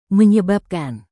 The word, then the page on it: menyebabkan: Indonesian